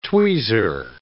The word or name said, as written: tweezer